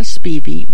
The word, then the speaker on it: Spivey: The reunion of Victoria Spivey, that's how she pronounced it, and Lonnie Johnson.